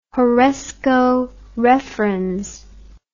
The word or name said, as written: horresco referens